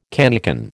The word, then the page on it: cannikin: American English